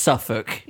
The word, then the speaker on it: Suffolk: How do Americans pronounce it? – Suffolk. – I've heard like Suffolk County. – Suffolk County. It's just Suffolk.